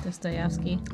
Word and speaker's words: Dostoevsky: So his verses are, like, lengthy, nonstop bars for days, like Dostoevsky, or however you say his name. – Dostoevsky.